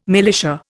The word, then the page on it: militia: Indian